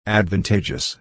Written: advantageous

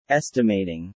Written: estimating

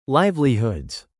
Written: livelihoods

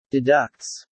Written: deducts

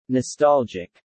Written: nostalgic

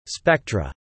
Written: spectra

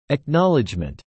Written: acknowledgment

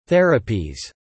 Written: therapies

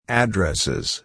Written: addresses

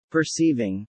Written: Perceiving

